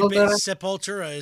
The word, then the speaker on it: Sepultura: We got Sepultura. I don't know how to pronounce it. I'd pronounce it like the Finnish, though. – That's actually Sepultura is basically how they pronounce it here. So yes.